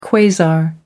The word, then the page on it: quasar: American English